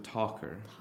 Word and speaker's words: talker: So 한국어로 표기하면은 원래 talk인데 어인데 토 되잖아요. – 네, talk. – 근데 맞는 모음은 어예요. So talker.